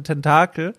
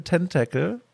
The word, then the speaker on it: Tentacle: Alle sagen immer Day of the Tentacle, aber Day of the Tentacle.